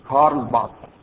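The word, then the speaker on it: Karl Barth: B-A-R-T-H این هجی و حروف انگلیسی کلام اسم این آقاست کارل بار. اون TH را معمولاً به سه سه نقطه ترجمه می کنن کارل بار. حالا ما دیگه عادی که میگیم کارل بار مثل کوسر که میخوام تلفظ کنیم یه خود مشکله. نباید دال بشه نباید سین بشه نباید ساد بشه یه خورده بینابین.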